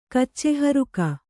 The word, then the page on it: ಕಚ್ಚೆಹರುಕ: Kannada